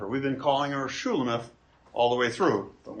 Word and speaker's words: Shulamith: Once again, her name embodies the same consonants as Solomon's name, but feminized in its vowels so that we pronounce it Shulamith.